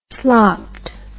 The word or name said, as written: slopped